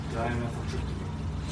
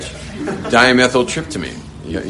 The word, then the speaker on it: dimethyltryptamine: Anyone know how to pronounce that thing? – Dimethyltryptamine. – Well, thank you very much. Dimethyltryptamine.